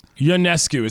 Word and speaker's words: Ionescu: Ionescu is how you pronounce that.